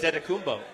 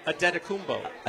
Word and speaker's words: Adetokounmpo: But Adetokounmpo. You're welcome. – Say it again? – Adetokounmpo. – Adetokounmpo. – You don't have to really overemphasize the T's.